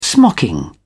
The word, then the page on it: smocking: British English